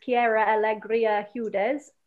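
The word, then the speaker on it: Quiara Alegría Hudes: So obviously this was a musical by Lin-Manuel and Kiera Alegría Hudes. Apologies if I mispronounced that. – That sounded right to me.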